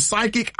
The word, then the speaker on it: psychic: So you're right, because when we see psychic, it's spelled P-S-Y, right? – I couldn't tell you. – Yes, yeah, this, yep. – So the P is silent.